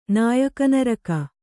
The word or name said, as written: ನಾಯಕನರಕ